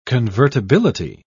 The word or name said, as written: convertibility